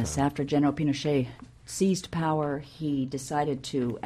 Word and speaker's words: Pinochet: Well, I think Pinochet is the right pronunciation.